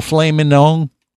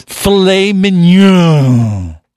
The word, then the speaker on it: filet mignon: Filet mignon for everyone. – Yeah, that's right. A little filet mignon.